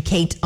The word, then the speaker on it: Kait: I am on Facebook and Instagram just by simply searching Kate on the Radio. My name is spelled kind of weird, K-A-I-T.